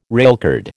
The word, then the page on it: railcard: American English